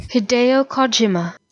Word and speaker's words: Hideo Kojima: Hideo Kojima. – Kojima. – Well, the part I thought I knew, apparently I didn't, and the part I wasn't sure about. – So Hideo Kojima.